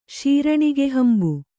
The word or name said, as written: ಶೀರಣಿಗೆಹಂಬು